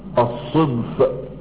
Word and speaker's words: الصدف: وقف بنا الحديث في المحاضرة السابقة عند احمد 236، وهو احمد ابن محمد ابن يونس المكنى بابي جعفر الصدفي. قالوا هذه النسبة صدفي، نسبة الى الصدف كما انطقه هكذا الصدف.